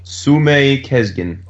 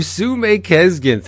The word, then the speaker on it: Sumeyye Kesgin: Maybe we can talk about the f- first two issues uh, written by Jay Faerber, Illustrated, I'm going to butcher this. I'm sorry. – Sume Kesgin. – Oh, Sume Kesgin. Thank you, for lighting my way.